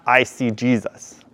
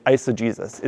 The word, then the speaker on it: eisegesis: That's spelled I-S-O. How do you spell G-G. It's not I see Jesus, which is a great way to remember it, but it's eisegesis.